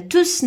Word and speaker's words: tous: Et dans ce cas, on doit prononcer le S, ok Il est là pour remplacer quelque chose et toujours quand ça se passe, on prononce le S. Alors, on répète, on a tous nos questions.